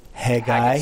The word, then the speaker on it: Haggai: Haggai? How do you say it? – Haggai. – Haggai. Haggai. – Not Haggis.